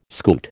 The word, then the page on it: scoot: American English